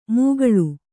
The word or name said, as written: ಮೂಗಳು